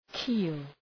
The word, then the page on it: keel: ki:l